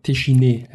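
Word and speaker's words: Téchiné: Ein französischer Film von André Tichin, der verfolgt uns schon Tichiné heißt das, gell? Oui.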